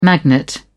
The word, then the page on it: magnet: British English